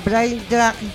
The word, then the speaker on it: Braindrag: presentamos a Brain Drag, lo digo bien.